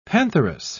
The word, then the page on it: pantheress: British English